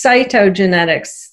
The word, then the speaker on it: cytogenetics: I saw that you have a master's degree in something called cytogenics. Am I pronouncing that correctly? – Yeah, it's cytogenetics. C-Y-T-O, which yeah, just means cytogenetics.